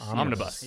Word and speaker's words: Omnibus: Omnibus. – You got to say it fast.